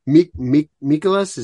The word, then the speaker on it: Mikolas: You know, the one thing I'll say about that second game though against the Cardinals, Mikolas, is that how you say his name? – Yeah.